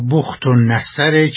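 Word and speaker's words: بخت‌نصر: اما بخت و نصر که درباره تلفظ این کلمه هم دارن اختلاف هستن. – بله، صحیحش بنو کد نصر یا بخت و نصره که جناب عالی هم صحیح تلفظ فرموده.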